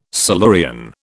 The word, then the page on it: Silurian: British English